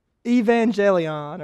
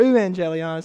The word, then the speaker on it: euangelion: It's the word evangelion, or euangelion is how I like to pronounce it.